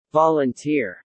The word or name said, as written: volunteer